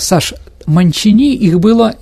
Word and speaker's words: Манчини: Но во французском языке, дорогие друзья, ударение всегда на последнюю гласную. Но она была итальянка, поэтому на Манчини.